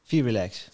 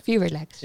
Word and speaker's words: VR Relax: want jij bent dus de co-founder van het bedrijf Vimer en de co-founder van het bedrijf VR Relax. Ja. Zeg ik dat goed? – VR Relax. VR Relax. Ja. – Moet je gewoon snel uitspreken.